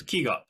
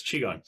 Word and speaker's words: Qigong: And he thought I would like this thing called Qigong, which was spelled with a Q. And I thought it was Qigong, but it's Qigong.